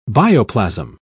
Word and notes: bioplasm: British English

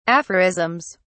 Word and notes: aphorisms: British English

